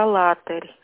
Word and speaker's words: Алатырь: Правильно называется «Алатырь». – «Алатырь». – Да. – Извините. – Ударение делайте.